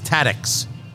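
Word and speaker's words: Tadych's: and I think what's pronounced Tadix, T-A-D-Y-C-H.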